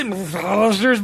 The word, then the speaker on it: McAllister's: Did I say McAllister's, by the way? – You said it right.